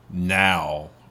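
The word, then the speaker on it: Nao: And I'm pretty sure that's not how you say it. Somebody told me that's how it's spelled, but it's actually like now. Or something like that. – Oh. – But it's N-A-O.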